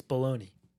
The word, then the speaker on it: bologna: no, Um, no, it's uh, it's bologna. – Yeah, that's right. Yeah, Just the G like is silent.